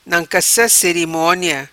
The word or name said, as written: Nancassa Cerimonia